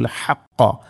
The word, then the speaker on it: الحق: ഇവിടെ അൽ ഹഖ്ഖ, ഹഖ്ഖ എന്നതിലെ ഹാവും ഖാഫും ഉച്ചാരണം പ്രത്യേകം ശ്രദ്ധിക്കണം. അൽ ഹഖ്ഖ എന്ന് പറഞ്ഞത് ولا تلبسوا الحق എന്നല്ല, ولا تلبسوا الحق. ഹാ തൊണ്ടയുടെ മധ്യഭാഗമാണ് അതിൻ്റെ മഖ്റജ്, ആ അക്ഷരം വരുന്ന സ്ഥലം.